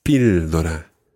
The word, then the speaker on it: píldora: Yeah, it's actually got an accent on the I. Una píldora.